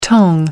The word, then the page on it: tang: American English